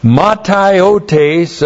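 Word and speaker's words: Mataiotes (ματαιότης): Insist on it in the Lord that you must no longer live as the Gentiles do in the mataiotes of their mind. That's the Greek word there. Looks like that in the Greek. M-A-T-A-I-O-T, long E-S.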